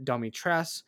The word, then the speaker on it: Dimitrescu: So I see Dimitrescu and I say that's Dimitrescu. However, she says it Domitres, but then there are other people like the Duke who call it, you know, Dimitres. So there's a disconnect between other characters in the game and the character you play and the main character who has the name, how it's pronounced.